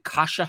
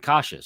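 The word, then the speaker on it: Kaše: Kasha. – Is that how we say his name? – Yeah, Kasha. – And Kasha.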